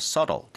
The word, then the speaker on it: subtle: So, subtle 하다는 건 발음 자체도 subtle이 아니라 subtle 되겠고요. 더 교묘한, 뭔가 더 눈에 띄지 않는 이라고 합니다.